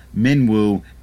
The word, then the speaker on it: Minwu: There are some, as we talked about in a previous episode, that we're going to change, but Minwu is how it's pronounced on the Final Fantasy Weekly Pod. – And it is spelled M-I-N-W-U, so I'm not sure how else I would pronounce that. You and your study of Japanese, do you have any insight? – It should be Minwu. Yeah, okay. Minwu sounds right.